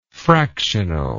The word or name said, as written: fractionally